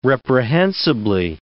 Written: reprehensibly

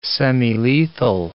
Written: semilethal

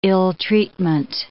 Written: ill-treatment

